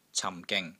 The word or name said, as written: CAM4 GING3